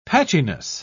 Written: patchiness

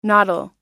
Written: noddle

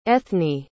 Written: ethne

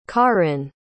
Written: caryn

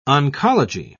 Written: oncology

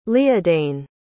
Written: líadáin

